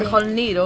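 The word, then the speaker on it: Mjölnir: Mjolnir. – Eso es, pues así se llama el martillo. – Porque supongo... – ¡Noller! – Esto es una jota. – Sí, Mjolnir o Mjolnir.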